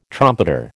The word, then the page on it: trumpeter: American English